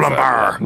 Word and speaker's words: Lambert: Christophe Lambert.